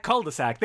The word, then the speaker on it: cul-de-sac: Is that how you say it? How cul-de-sac? Cul-de-sac. There you go.